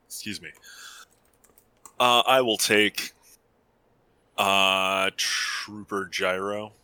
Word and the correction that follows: Gyro: It's pronounced Euro. – Oh, it's Euro? – Yes.